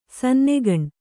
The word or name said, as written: ಸನ್ನೆಗಣ್